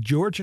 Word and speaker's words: Gyorgy: Het wordt geschreven met G-Y-O-R-G-Y. En voor iedereen die wel eens in Hongarije geweest is, die weet dat die laatste Y niet wordt uitgesproken. Of althans, niet op die manier. Dus het moet uitgesproken worden als George.